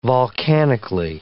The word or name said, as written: volcanically